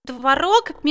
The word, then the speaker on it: творог: А вот в слове «творог» Мел Чернилыч, я запутался. Я слышал, как одни говорят «творог», а другие «творог». Как правильно? – Правильно делать ударение в этом слове на второй гласной. Творог.